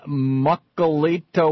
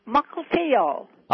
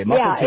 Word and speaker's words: Mukilteo: Mukilteo, Washington. Am I pronouncing that right? – Yeah, you didn't pronounce it. No, it's Mukilteo. – Ah, there you go. Okay, Mukilteo. – Yeah, it's Mukilteo, Washington.